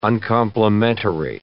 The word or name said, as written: uncomplimentary